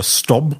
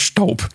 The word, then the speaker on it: Staub: Es gibt zum Beispiel die französische Firma Staub oder Stob oder wie immer die sich aussprechen. – Die heißt nämlich Staub.